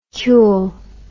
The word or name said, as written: cule